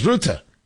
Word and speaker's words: Rutte: Now, we can equally say that Mark Rutte, or pronounced sometimes Rutte,